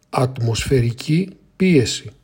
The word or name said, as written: Ατμοσφαιρική πίεση